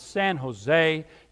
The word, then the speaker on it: San Jose: The J is silent. It's San Jose.